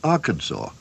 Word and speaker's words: Arkansas: It's spelled like Kansas with A-R in front. Why, then, is it pronounced Arkansas?